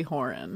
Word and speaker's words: Horan: My niece's Twitter handle is Happily Horan. – Oh. – Is that how you pronounce it? Yes, and Happily is the name of a song.